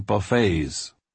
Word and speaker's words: buffets: The suffering servant passage from Isaiah is proclaimed, "He did not shield his face from buffets." Not pronounced as it should be, like Warren and Jimmy's last name, but as in hometown, all you can eat.